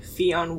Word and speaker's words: Fionn: That follows, yeah, Tommy, played by Fionn Whitehead. That's the only way I can pronounce it. – I think that's pretty accurate. Fionn.